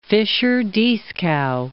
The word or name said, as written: Fischer-Dieskau